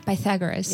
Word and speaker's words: Pythagoras: I think, well, at that time as well, so the, how do you pronounce it? Pythagoras? The one who made the theorem. – I think you're pronouncing it right. – Pythagoras. Yeah. Okay, so that guy.